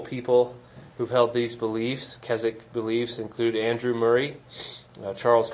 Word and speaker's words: Keswick: spelled Keswick, if you've read about it, but it's pronounced Keswick, don't ask me why.